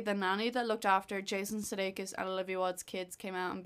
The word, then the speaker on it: Sudeikis: How do you pronounce his second name? – Jason Sudeikis. – Sudeikis, thank you.